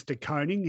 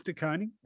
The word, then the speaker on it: De Koning: I think his tweet said something like it's De Koning's world, we're just living in it. Look, probably the most critical duel of the match, really, in some ways, De Koning on and it is De Koning.